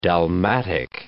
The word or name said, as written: dalmatic